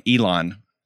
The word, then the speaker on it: Elon: It's like elongate Elon. His name. Okay. Elon. – Elon. Elongate it.